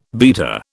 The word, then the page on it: beater: British English